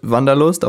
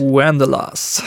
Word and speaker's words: Wanderlust: Und in, dem, in eurem Song, also wir sagen mal, er heißt, wie spricht man ihn aus? Auf Deutsch Wanderlust, auf Englisch? Wanderlust. Wanderlust.